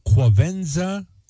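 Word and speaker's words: Quvenzhané: Okay, they have a pronunciation key, so let's try this together. Kvenzen. All right, Kvenzen A. Wallace.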